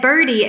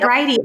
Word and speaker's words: Bridie: So Bridie, because when I read it, my little brain read birdie. And then when you said Bridie, I was like, oh, Michelle, you butchered your spelling there.